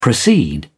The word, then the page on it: proceed: British English